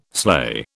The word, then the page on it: sleigh: British English